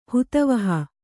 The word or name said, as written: ಹಿತಾವಹ